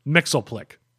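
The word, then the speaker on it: Mxyzptlk: Mr. Mixelplick.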